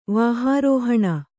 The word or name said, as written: ವಾಹಾರೋಹಣ